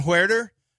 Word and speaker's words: Huerter: Kevin Huerter. Or Herter, I think it's pronounced, 13.5, Atlanta at Milwaukee.